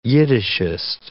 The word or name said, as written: Yiddishist